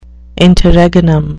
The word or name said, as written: interregnum